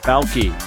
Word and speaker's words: Bauke: I am joined by Julie Bauke. Am I saying that right, Julie? – Yep.